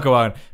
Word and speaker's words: Guoan: It definitely sounds like Guoan. It's spelled G-U-O-A-N. Guoan. – Go on.